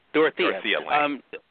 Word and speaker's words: Dorothea Lange: Dorita Lange, but also, of course, great interviews as well. I want to talk about one of the photos by, is it pronounced Dorothea or Dorita? – Dorothea Lange.